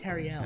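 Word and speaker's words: Tauriel: I'm like, Talia. It's pronounced Tariel.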